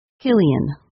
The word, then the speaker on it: Cillian: His first name is pronounced Cillian.